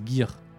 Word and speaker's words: Gear: Il y a débat sur la prononciation de Gear. – Non, mais Gear. Oui, normalement c'est Gear.